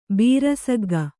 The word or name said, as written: ಬೀರಸಗ್ಗ